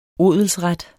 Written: odelsret

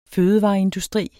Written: fødevareindustri

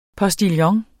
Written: POSTILLONS

